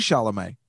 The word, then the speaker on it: Chalamet: Timothy Shaw. How do you... – Chalamet. – Oh, it is Chalamet.